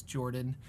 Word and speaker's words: Jorphdan: My name is Jorphdan, with a silent PH in the middle, and I'm joined always by my uh, wonderful co-host, Sir Lucien, over at Sir Lucien Gaming.